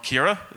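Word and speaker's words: Ciara: Last week we had Storm Kira. Is that right? Is that the right way to pronounce it? Yeah. This is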